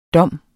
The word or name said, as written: dom